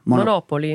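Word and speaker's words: Monopoli: How do you pronounce Monopoly over there? – Monopoly.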